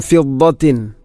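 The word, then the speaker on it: ഫിദ്ദത്ത് (فضة): അസാവിറ മിൻ ഫിദ്ദത്തിൻ, ഫിദ്ദത്തിൻ വസഖാഹും. ഫിദ്ദത്ത് നേരത്തെ പറഞ്ഞതുപോലെ ദാദാണ് അതിൻ്റെ മഖ്റജ്. ദാദിൻ്റെ ഉച്ചാരണം പ്രത്യേകിച്ചും ശ്രദ്ധിക്കേണ്ടതാണ്. അപ്പോൾ അതിൻ്റെ മഖ്റജ് അണപ്പല്ലുകളോട് ചേർന്ന് നാവിൻ്റെ ഏതെങ്കിലും ഒരു സൈഡ്.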